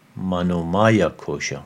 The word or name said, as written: Manomaya Kosha